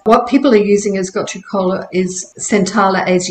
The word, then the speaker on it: gotu kola: Another example is I think a lot of people have heard of gotu kola, I never know how to pronounce it right. – No, you've got it right.